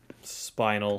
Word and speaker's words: spina: Raffield says the producer wanted to use an actor who was handicapped, so he found a kid who had spinal. – The kid had. – Assume the first word is. – No, it's S-P-I-N-A. – Spina. Or really, spina? That's how that's pronounced.